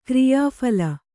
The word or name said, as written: ಕ್ರಿಯಾಫಲ